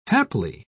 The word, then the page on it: haply: British English